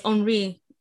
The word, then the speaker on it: Henry: Yeah, it's Henry.